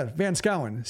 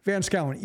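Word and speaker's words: Van Schouwen: And like, I didn't know how Dutch words are pronounced or like how some combinations, because they're founded by Dutch, sounded completely different, which was great for like knowing how to pronounce Daryl Van Skouwen's name before hearing it.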